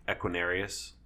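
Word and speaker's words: Equanimeous: How do you say his first name? – Equinarius.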